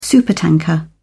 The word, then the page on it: supertanker: British English